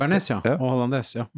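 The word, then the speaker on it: hollandaise: Så den her kommer til å være en sånn der dans gjennom hollandesen, eller hollandes, jeg er litt ute på sånne franske ord. Ja, hollandes, ja. – Du uttaler resten på slutt, faktisk. – Så jeg kan ikke si hollandé? – Nei.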